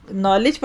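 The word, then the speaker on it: knowledge: जैसे इंग्लिश में आप लिखते हैं नॉलेज, अब के बाद क्या लफ्ज़ आता है स्पेलिंग में, एन, तो पढ़ते किसको है, के को नहीं पढ़ते, नॉलेज पढ़ते हैं ना, तो वो के साइलेंट होता है, इसी तरह यहाँ मिन साइलेंट में आएगा।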